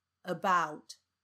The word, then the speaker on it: about: 那接下来我就举几个例子：about about about 这个字，它重音在第二个音节 bout。所以第一个音节 a，你就把它念成呃呃，about，sofa，sofa，sofa，